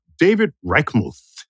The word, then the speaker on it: David Reichmuth: we found a PhD chemical engineer named David Reichmuth.